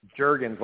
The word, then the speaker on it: Juergens: Juergens, like the lotion.